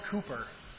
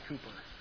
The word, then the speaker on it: Cowper: It's pronounced Cooper. I don't know if you saw it, but it looks like Cowper. C-O-W-P-E-R. It's actually pronounced Cooper.